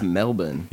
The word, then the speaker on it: Melbourne: It has an R in there. – It's Melbourne. But that's not how Aussie locals say it.